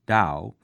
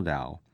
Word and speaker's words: Tao: The Chinese word Tao, which is spelled T-A-O but pronounced D-O-W, literally means way.